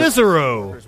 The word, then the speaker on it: Cicero: I think I'm saying that right. – Amazon facility in Cicero, Illinois. – Cicero. – Walked out this morning. The group. – I said it like I was from Alabama.